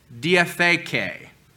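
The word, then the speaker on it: diatheke (διαθήκη): You can spell it in English, B-R-I-T-H, bereth, and the Greek term diatheke, D-I-A-T-H-E-K-E would be the English way of spelling that.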